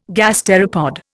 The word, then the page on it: gasteropod: Indian